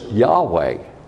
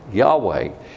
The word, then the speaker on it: Yahweh: And God's proper name is Yahweh. Yahweh. It is the most biblically consistent, Hebrew consistent way to pronounce the four letters.